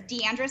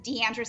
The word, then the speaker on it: D'Andra: Yes, yeah, so on Twitter, it's Deandra Simmons, D-A-N-D-R-A Simmons, S-I-M-M-O-N-S. Deandra Simmons on Facebook, Deandra Simmons on Instagram. And my name is spelled D-apostrophe, capital A-N-D-R-A Simmons, S-I-M-M-O-N-S.